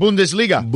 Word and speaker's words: Bundesliga: The German, the Bundesliga. How you say it? – Bundesliga.